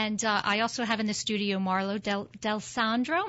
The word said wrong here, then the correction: Del Sordo: Am I pronouncing that right? Del Sordo. Del Sordo.